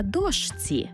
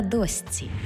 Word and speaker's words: дошці: І не «на дошці», а «на досці».